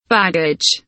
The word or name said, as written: baggage